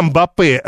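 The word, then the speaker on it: Mbappé: Да, Мбаппе, ну вот я почему-то привык и не могу ничего с собой поделать. Во французском все ударения на последнем слоге.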